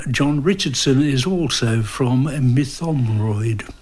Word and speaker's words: Mytholmroyd: And it's in the West Yorkshire village of, I'm trying to remember how to pronounce it, it's Mytholmroyd.